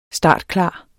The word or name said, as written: startklar